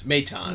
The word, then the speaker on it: Maton: How do you say it? – Maton! It's Maton. – Maton. – I pronounce it French. – For some unknown reason. – It sounds like Baton.